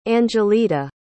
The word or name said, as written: angeletta